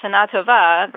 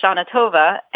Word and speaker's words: Shana Tova: Well, there's also an interesting influence from English here, because English stresses most of its word on the penultimate syllable. And Hebrew, modern Hebrew, stresses most of the words on the ultimate syllable, mm-hmm. the last syllable. So, Shana Tova. – Shana Tova versus Shana Tova. And Shana Tova fits in better to the sound structure of English.